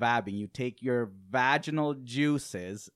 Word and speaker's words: vabbing: And it's called vabbing. Have you heard of it? – No. – V-A-B-B-I-N-G. Okay. – Never heard of it. – Vabbing. Now, it's vabbing.